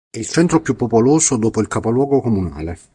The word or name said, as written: comunale